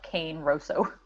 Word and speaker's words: Cane Rosso: And for the people that don't understand the British accent, it's basically spelled Cane Rosso. – Oh, yeah. That's the Italian pronunciation.